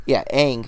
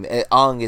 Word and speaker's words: Aang: The cartoon is fantastic, and Aang, Aang, that's the right name. Aang is how M. Night Shyamalan pronounced it, and that people nitpicked.